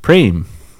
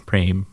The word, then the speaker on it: Prem: And then Prem, or if you go to Punjab in northern India, they would pronounce it Prem. Prem, Prem, potato, potato, you know, all that.